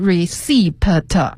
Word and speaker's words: receipt: You can present a receipt、这个 receipt 的拼写给大家再拼一下，是 R-E-C-E-I-P-T，它最后呢是有一个 P 的，但是呢我们不读出来，对，这个 P 是不发音的、所以不念成 receipt 而是 receipt。